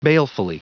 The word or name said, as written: balefully